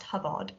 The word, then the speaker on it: Hobart: Henrietta's father, a man called Henry Hobart, who I'm reliably informed his name should actually be pronounced Hubbard.